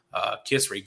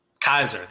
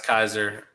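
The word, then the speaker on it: Kysre: Kisri Gondrzik, that was drafted, I believe, fourth. Her name is not pronounced like that. Kaiser. There we go. It is pronounced Kaiser.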